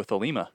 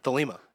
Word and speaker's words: Thelema: Oh, Thelema. – Thelema. – Yeah, Thelema. Yeah, that was a religious. – You can tell I learned that word by reading. – Yeah, I've heard about Thelema.